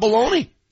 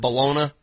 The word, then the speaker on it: bologna: I'd rather talk about baloney. – No doubt. No doubt. – And if you pronounce it Bologna, you're a loser, okay? I know it's spelled that way. It's bologna.